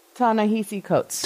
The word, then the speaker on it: Ta-Nehisi Coates: Ta-Nehisi Coates. – Ta-Nehisi Coates.